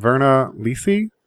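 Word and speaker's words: Virna Lisi: William Holden and Verna Lisi? Is that how you say her name? – Sure.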